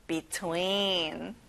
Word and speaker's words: between: 자, E가 두 개니까 길게 끌어보세요. Between. Very good.